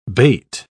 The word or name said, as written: bait